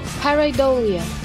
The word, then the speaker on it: pareidolia: And by the way, the term for seeing patterns in objects, how do you pronounce this? – Pareidolia. – Pareidolia.